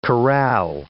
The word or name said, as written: corral